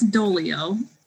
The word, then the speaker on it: Doglio: I'm really looking forward to today's interview because we are talking to Beth Dolio. And if you're looking for her information on the internet, it's spelled like Doglio, D-O-G-L-I-O, but it's pronounced Dolio. The G is silent.